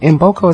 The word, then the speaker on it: Mboko: I want to say Mboko. Mboko is how you say it? Victoria Mboko is M, is K-M-B-O-K-O.